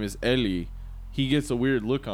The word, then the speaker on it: Eli: the name is Eli. The way they pronounce it is Ellie.